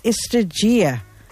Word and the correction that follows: Estrategia: I hope I'm pronouncing that correctly. – It's actually Estrategia.